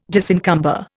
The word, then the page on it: disencumber: Indian